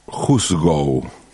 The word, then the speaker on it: hoosegow: La palabra juzgo juzgo se escribe H-O-O-S-E-G-O-W, juzgo Digo, se escribe así porque si la ves escrita sin pronunciarla, nos va a parecer mucho más lejana, pero si la pronuncio, podemos entender más o menos por dónde viene juzgo que es como una, una palabra del lenguaje viejo del oeste que significa cárcel.